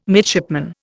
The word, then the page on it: midshipman: Indian